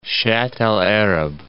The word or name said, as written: Shatt al Arab